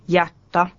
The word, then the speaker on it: hjärta: Konsonanttiyhdistelmissä DJ, GJ, HJ ja LJ jäävät D, G, H ja L ääntämättä. – DJ esimerkiksi djur, eläin. GJ esimerkiksi jyyttä, valaa. HJ esimerkiksi jättä, sydän.